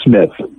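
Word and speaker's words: Smyth: So to try to show what a great guy he is, the suspect, Benjamin, is it Smythe or Smith? – I believe it is just Smith. – Because it's spelled with a Y. – It is spelled with a Y, yes.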